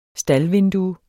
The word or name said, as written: staldvindue